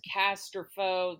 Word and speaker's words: Casterfo: No, well, also it is Casterfo. They got it wrong on the audiobook.